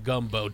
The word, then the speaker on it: gumbo: First, you must take your magical item and control someone who knows how to make a Goombo. Master Merrick, it's pronounced gumbo.